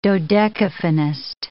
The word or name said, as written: dodecaphonist